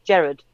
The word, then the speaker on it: Gerard: I think you pronounce it the Scottish way, which is Gerard.